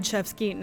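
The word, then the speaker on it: Rzewski: And three pieces of Fred Shevsky. Now, I've heard it always pronounced Shevsky, but it's spelled with an R.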